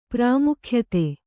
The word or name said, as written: ಪ್ರಾಮುಖ್ಯತೆ